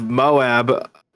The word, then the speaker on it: Moab: But speaking of Moab, did I say it right that time? – Yes.